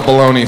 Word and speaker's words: bologna: All right, how do you want to pronounce this word? Baloney! I don't know if you saw, there's a G in the word. I don't see no G.